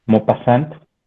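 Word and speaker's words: Maupassant: A mí este autor, kit se, pronuncia Kit eh, e Mopassant.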